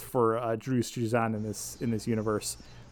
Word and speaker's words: Drew Struzan: For those who aren't familiar, all the art was actually done by Drew Struzan, I think his name is pronounced.